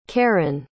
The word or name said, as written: karrin